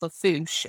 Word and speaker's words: LaFourche: So the schools out in LaFourche, it's pronounced LaFourche. It looks like LaFourche, but it's LaFourche Parish.